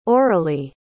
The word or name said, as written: aurelea